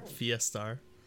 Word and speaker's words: Fiestar: It's Fiesta and Star. Fiestar.